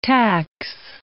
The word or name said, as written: tax